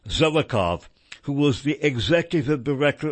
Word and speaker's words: Zelikow: Zelikow, or Zelikow, Z-E-L-I-K-O-W, I'm pronouncing it Zelikow, who was the executive director of the commission that investigated September 11.